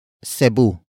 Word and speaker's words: Cebu: And it is at Cebu. Cebu. Cebu. Cebu is spelled as capital C-E-B-U.